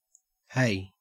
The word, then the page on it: hey: Australian English